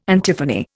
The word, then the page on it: antiphony: Indian